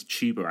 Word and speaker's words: Chuba: I think it's Chuba actually is how you pronounce his name, but get Hubbard in.